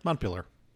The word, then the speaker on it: Montpelier: Montpelier. Is that how you pronounce it? – Yeah. – Montpelier? – Montpelier.